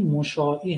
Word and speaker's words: مشاعی: تأکید میکنم این تلفظ صحیح مشاعی هست. البته مشاع با همزه هم ممکنه دوستان شنیده باشن، اون به معنای دیگری است، لفظ دیگری است، با این هم نوشته نمیشه.